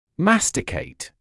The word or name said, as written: masticate